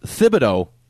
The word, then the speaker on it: Thibodeau: It's pronounced Thibodeau, but apparently he goes by the nickname of Tibbs.